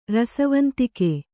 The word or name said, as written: ರಸವಂತಿಕೆ